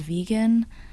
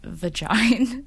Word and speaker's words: Vegyn: This one is by, I want to say it's pronounced vegan. I really hope it's not vagine, because it's spelled V-E-G-Y-N, so I'm just gonna say vegan.